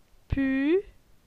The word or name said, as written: Pu